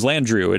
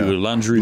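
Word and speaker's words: Landrieu: Landru. It's L-A-N-D-R-I-E-U. Landru. – Landru. They pronounce it real weird.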